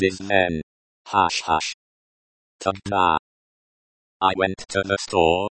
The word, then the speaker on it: тогда: Is pronounced like, тогда, with the stress on the second syllable.